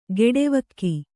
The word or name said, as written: ಖಡಾವಿಗೆ